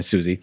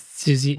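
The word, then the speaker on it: Siouxsie: I think it's funny that they keep pronouncing it Suxi, you know, Susie. – It's Susie. Yeah, Susie and the Banshees.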